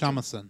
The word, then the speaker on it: shamisen: shamisen.